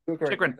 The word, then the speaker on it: Chychrun: Chychrun.